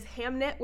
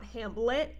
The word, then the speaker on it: Hamnet: It is Hamnet with an N and not Hamlet.